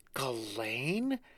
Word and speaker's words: Ghislaine: Is that how you say it? Ghislaine? – Mm-hmm. – I thought it was like... – You thought it was Ghislaine? – Ghislaine, yeah. – No, you remember by Ghislaine in Spain steals children for her boyfriend to have sex with.